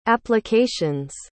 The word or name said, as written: application's